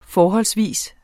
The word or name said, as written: FORHOLDSVIS